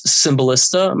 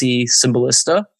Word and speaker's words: Cymbalista: And I don't have a fan Facebook page, but I have my personal Facebook page is Gabe Bez Cymbalista. My last name is spelled Cymbalista. My middle name is Bezalel. So I just do Gabe space Bez Cymbalista. My LinkedIn is Gabriel Cymbalista. Gabe Cymbalista is my Twitter.